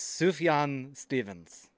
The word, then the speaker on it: Sufjan Stevens: And I was like, how do you, it's misspelled, but you, so who in the hell is Sufjan Stevens?